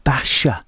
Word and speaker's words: Basia: How about a little bit of Basha? It's spelt Basia, B-E-B-A-S-I-A, a fabulous lady with a Polish voice.